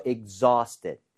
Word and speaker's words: exhausted: AU나 AW 스펠링은 대부분 어에 가깝게 발음이 돼요. – 네. – 아우가 아니라. – 예. – So exhausted, exhausted exhausted. – 이렇게 발음을 하는 게 맞습니다.